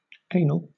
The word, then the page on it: anal: British English